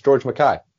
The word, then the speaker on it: George McKay: You're pronouncing his name wrong. It's George McKay.